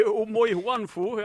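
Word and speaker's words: Omoijuanfo: Han Ohi Omoihuanfo. – Ja. ja, riktig. Det var helt korrekt uttalt.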